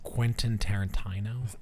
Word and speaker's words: Quentin Tarantino: It's Quentin Tarantino.